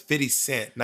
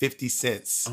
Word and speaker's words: 50 Cent: No, it's 50 Cent, not 50 Cents.